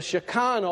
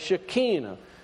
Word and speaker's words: Shekinah: One, if we look at, and we said this last week, but it's the Shekinah or the Shekinah. However way you would like to pronounce it, it's pronounced both ways.